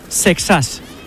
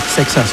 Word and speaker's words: Seixas: Exactamente os lo voy a decir. Lo que pasa es que luego cada uno lo dirá como quiera, pero es Seixas. Paul Seixas. – Seixas. – Así se pronuncia, Seixas.